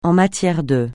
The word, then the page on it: en matière de: français